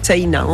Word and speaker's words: Seina: Ça s'écrit un peu comme la Seine, mais... – Voilà, avec un A à la fin, Seina, on prononce le I aussi. – Comme si on avait un tréma au-dessus.